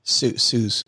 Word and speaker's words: Suze: Do you pronounce it Suze or Susie Orman? – Suze Orman.